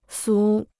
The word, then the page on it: 俗: sú